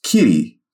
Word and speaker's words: Kiri: So Kiri, Sigourney Weaver's character, it's pronounced Kitty. And so I thought her name was Kitty. Like kitty cat. Oh. Throughout the whole movie, I didn't think it was Kiri, but like with an accent.